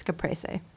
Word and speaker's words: Caprese: Caprese salad, is that how you pronounce it? – I think it's caprese. – Caprese, okay.